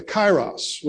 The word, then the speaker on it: Kairos: I am a great believer in the principle of the critical time, or the kairos, which is the Greek word. It's spelled in English, K-A-I-R-O-S. Kairos.